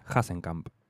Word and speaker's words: Hasenkamp: Bien, llegó el momento de escuchar a un pibe que viene de un pueblo llamado, perdón si lo pronuncio mal, pero entiendo que es Hasenkamp, en Entre Ríos, no es en Alemania ni nada por el estilo, hablamos de, de artistas nacionales.